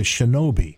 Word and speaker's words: Shinobi: Okay, then, and now I don't remember, I think it's Harker is on the road, and he's attacked by the shinobi.